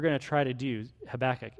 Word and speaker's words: Habakkuk: Habakkuk is how you would pronounce that.